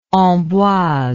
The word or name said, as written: Amboise